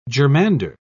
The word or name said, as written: germander